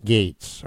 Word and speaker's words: Gaetz: Matt Gaetz.